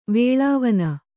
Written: ವೇಳಾವನ

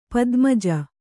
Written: ಪದ್ಮಜ

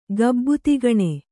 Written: ಗಬ್ಬುತಿಗಣೆ

